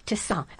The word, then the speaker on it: tisane: Oh, this is T-I-S-A-N-E. Tisane. I always thought it was a tisane, but that's a Midwestern pronunciation of it. – Well, I've always called it tisane, which is also fairly common. – Okay. – It's kind of that tomato-tomato thing.